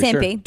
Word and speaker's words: Tempe: Is it Tempe, Arizona? Is that how you say it? – I'm pretty Tempe. – Sure. – Tempe. – Tempe. Okay. In Tempe, Arizona, police officers were asked to leave a Starbucks.